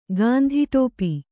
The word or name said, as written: ಗಾಂಧಿಟೋಪಿ